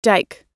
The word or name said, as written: dike